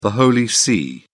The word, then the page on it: the holy see: British English